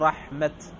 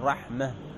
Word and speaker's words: رحمة: قال قوله الوقف في الأفصح على نحو رحمة بالهاء، وعلى نحو مسلمات بالتاء، فتقول رحمة إذا وقفت، ما تقول رحمة، واضح؟ لأن هذا في الحقيقة هو هاء وليس تاء.